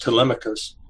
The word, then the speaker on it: Telemachus: The one is the novel, and it's pronounced Telemachus.